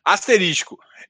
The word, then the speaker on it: asterisco: Eu falei, ixi, eu falei errado essa bagaça. É, eu fiz a mesma pesquisa que você, Marco. Acabou de sair aqui. Asterisco.